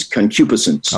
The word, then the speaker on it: concupiscence: So I looked it up with the Oxford English Dictionary that pronounces words, mm-hmm. and it is concupiscence.